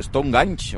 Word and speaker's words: Stonehenge: Per cert, que m'ha agradat molt com ho has dit, perquè jo sempre havia dit Stonehenge I tu has dit sí. Stonehenge o alguna cosa així? – Sí, perquè aquesta és la pronúncia que és la correcta, eh?